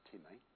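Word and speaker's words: time (τιμή): Now the Greek word that's used here in Romans 10 for honour is a word time, which is spelt like time, but it's not time, it's time.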